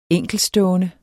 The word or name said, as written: enkeltstående